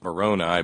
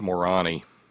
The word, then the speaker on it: Moroni: But Great Jumpin' Moroni, which I think we pronounce Moroni because it's otherwise it's Morani.